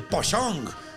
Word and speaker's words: Pocong: el Pochong que se escribe Pocong.